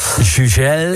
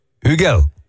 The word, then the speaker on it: Hugel: Ik heb hier de artiestennaam Hugo van de Franse DJ en producer spreek je op zijn Frans uit als Jugel. – Jugel. – Nou, doen we dat toch? – Maar als je het Duitse woord Hugel neemt, waar zijn naam vandaan komt, dan zeg je Hugel.